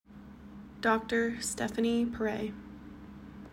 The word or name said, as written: Dr. Stephanie Pare